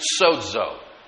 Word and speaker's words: sozo: And that word soter is very similar to another word spelled S-O-Z-O, pronounced sozo.